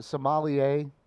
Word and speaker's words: sommelier: Like someone who is like is it a sommelier?